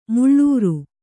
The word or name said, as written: ಮಿಳಿರು